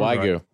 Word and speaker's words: Wagyu: Wagyu. – Right. Wagyu.